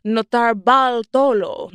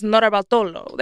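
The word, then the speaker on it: Notarbartolo: And it's Leonardo Notarbaltolo. No, so I did say it wrong. It's Notarbaltolo. There we go.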